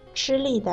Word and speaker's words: 吃力: 对，吃梨的梨是二声，吃力的力是四声，而且这两个词的意思也有很大的区别。